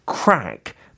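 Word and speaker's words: craic: Crack, but it's spelled C R A I see.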